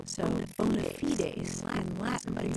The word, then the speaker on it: bona fides: I tell you, Marianne, I have always pronounced it bona fides. But you know, there are some authorities who have sneered at that pronunciation, and they say it should be bona fides.